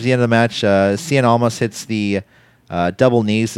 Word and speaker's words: Cien: Cien is one syllable, not two.